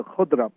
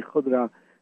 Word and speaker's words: khodra: For the vegetarian people, I truly recommend the khudra plate, and that's spelled K-H-O-D-R-A, khudra.